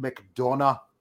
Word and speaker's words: McDonagh: Because you gotta say it like that, McDonagh, next week.